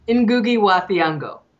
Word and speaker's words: Ngugi wa Thiong'o: Ngugi, is that it? What? – Ngugi. Ngugi wa Thiong'o.